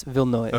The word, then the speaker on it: Villeneuve: I always said Villeneuve, but I looked up the pronunciation, and it's Villeneuve.